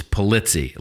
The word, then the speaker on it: Pulitzi: I'm like, it's Pulitzi, like itsy bitsy Pulitzi.